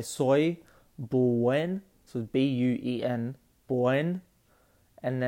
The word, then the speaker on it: soy: So soy, like soy sauce. So we have soy sauce, but soy in Spanish means I am.